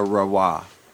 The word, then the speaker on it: Rewa: A Rewa. I asked some locals how it's pronounced, and they said Rewa.